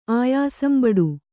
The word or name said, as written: ಆಯಾಸಂಬಡು